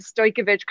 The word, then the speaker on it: Stojkovic: I do not get a lot of Stolkevich correct pronunciation.